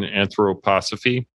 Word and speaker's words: anthroposophy: The declining interest in anthroposophy. Did I say that right? – Yep.